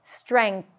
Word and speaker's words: strength: But if you want to be on the safe side, just go with streng, N-G, and then a T-H. Strength.